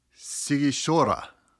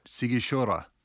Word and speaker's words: Sighișoara: So they think Dracula was born in the town of Sighișoara. I think I said that right. Sighișoara. – Sighișoara.